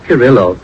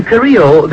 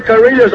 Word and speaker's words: Carrillo: Well, Charlie, it's not Carrillo. – No. – In Carrillo, the L is silent.